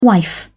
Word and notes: wife: British English

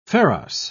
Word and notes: pharos: British English

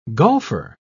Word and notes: golfer: British English

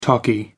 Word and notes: talkie: American English

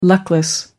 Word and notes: luckless: American English

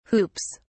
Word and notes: hoops: British English

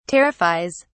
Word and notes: terrifies: British English

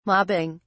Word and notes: mobbing: British English

